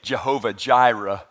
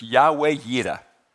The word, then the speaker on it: Yahweh Yireh: Now, we kind of butcher it. We say Jehovah Jireh. That kind of makes my heart sink just a little bit. It's in Hebrew, it's Yahweh Yireh. Okay, so it's not Jehovah Jireh.